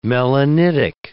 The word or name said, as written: melanitic